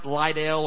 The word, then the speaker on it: Liddell: Eric Liddell, if you don't know much about him or if you never heard his name before, it's a lot of times mispronounced Liddell or Liddell. His last name is spelled L-I-D-D-E-L-L.